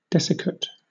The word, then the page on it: desiccate: British English